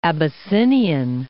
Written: Abyssinian

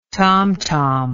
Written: tomtom

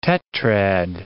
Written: tetrad

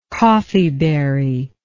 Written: coffeeberry

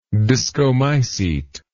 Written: discomycete

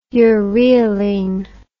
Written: ureylene

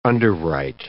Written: underwrite

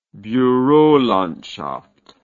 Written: burolandschaft

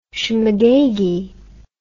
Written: schmegeggy

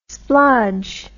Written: splodge

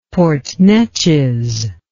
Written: Port Neches

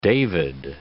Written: David

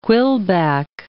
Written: quillback

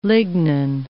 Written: lignin

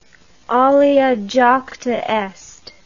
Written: alea jacta est

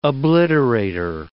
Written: obliterator

